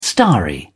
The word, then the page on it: starry: British English